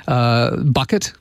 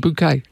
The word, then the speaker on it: Bouquet: Mrs. Bouquet, Bucket? But it's pronounced Bouquet.